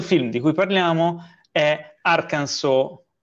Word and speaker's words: Arkansas: In America, come sapete, c'è il Kansas, che si scrive Kansas e si legge Kansas, e poi c'è Arkansas, che però si legge Arkansas.